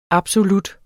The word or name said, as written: absolut